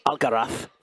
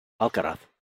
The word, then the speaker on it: Alcaraz: That's Alcaraz for the Castellano pronunciation. Alcaraz.